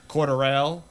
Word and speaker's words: Cordarrelle: I keep saying it wrong too. Cordarrelle.